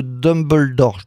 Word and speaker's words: Dumbledore: Les animaux fantastiques, le secret de Dumbledore, je dois mal le prononcer. – Non, c'est très bien.